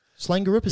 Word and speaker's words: Slangerup: And then in the Danish league on the 31st, the next night for Slanger, I believe it's Slangerup. Slangerup, is that how you pronounce it? – Yep.